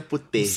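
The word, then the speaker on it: Putellas: Como que é a pronúncia aí? – É? Alexa Putejas.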